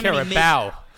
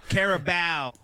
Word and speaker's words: Carabao: What, I forget how you pronounce that. – There's the so Carabao. – Carabao. I'm sorry.